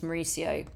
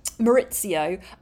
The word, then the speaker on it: Mauricio: Maurizio. Yes, I always say Maurizio. Remember, please. I know his name's Mauricio, but I pronounce it like Lisa Vanderpump. Maurizio.